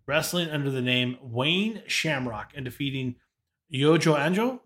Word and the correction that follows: Yoji Anjo: Am I saying that correctly? – That's the best. Yoji Anjo. – Yoji Anjo? I'm terrible. Sorry.